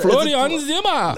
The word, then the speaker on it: Florian Zimmer: Oh, Florian Zimmer. Yeah, I have to say it more German. Florian Zimmer!